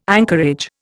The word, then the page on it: anchorage: Indian